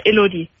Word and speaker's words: Elodie: Na, es wird ein bisschen anders ausgesprochen. Elodie. – Elodie. – Das ist ein französischer Name.